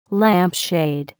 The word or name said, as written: lampshade